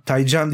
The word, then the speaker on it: Taycan: Ee, Porsche Taycan, Taycan diye okunuyormuş aslında. Çünkü Türkçeden gidiyor. Taycan. Ee, ben de hep Taycan diyorum da Taycan'mış yani orijinali Taycan.